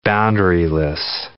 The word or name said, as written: boundaryless